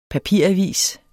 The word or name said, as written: papiravis